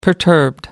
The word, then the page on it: perturbed: American English